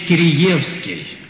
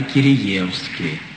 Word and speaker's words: Киреевский: и он меня поправил. Говорит, вы знаете, все-таки у нас его всегда звали Киреевский. Я бросился в словари, смотрю, два ударения стоит. И Киреевский, и Киреевский. Но с тех пор предпочел говорить Киреевский. Так что не удивляйтесь, если встретите и другое произношение.